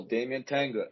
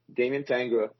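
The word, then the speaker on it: Damyan Tangra: But right now, it's a huge plus because you can follow Damien Tanga. It's D-A-M-Y-A-N-T-A-N-G-R-A, Damien Tanga, on all social media. That's my handle, Damien Tanga.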